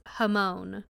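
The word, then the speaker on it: jamón: I don't know exactly what Buddy back there called it, but it's Hamon. – Hamon. – You can just say. – Yeah, Hamon.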